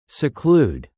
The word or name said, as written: seclude